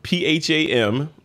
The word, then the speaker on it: Pham: Stephanie Pham, Pham, pronounced yes. Pham like family.